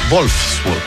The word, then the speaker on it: Wolfsburg: No, es Wolfsburg, porque la W se pronuncia como V corta, ¿no? O V, ¿no? Se dice V.